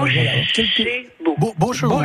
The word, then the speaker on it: Bochevo: Ça s'écrit B-O-C-H-E-V-O. – D'accord. Beau cheval. – Voilà. – Beau chevaux. Beau chevaux. Comme un cheval qui serait beau. – Un beau cheval. – Voilà. B-O-C-H-E-V-O.